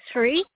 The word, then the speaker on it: Shree: Hi, it's Sri. – Can you spell that, please? – S-H-R-E-E.